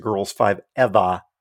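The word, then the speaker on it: Girls5eva: Did I say that right? Girls Five ever. I think I think that's that's pretty good.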